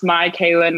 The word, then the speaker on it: Mikayla: I should be better at this, but my name is Mikayla. I always joke and say it's pronounced Mikayla, not your Kayla. Because there are a million like Mikaylas in the world, but I ain't one of them.